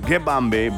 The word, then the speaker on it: Ngibambe: Pronounce it Nigbambe, Nigbambe, Gibambe. That's what I'm hearing, Gibambe, but spelled as N-G-I-B-A-M-B-E, Nigbambe, by Major Lazer in the G-A-G remix.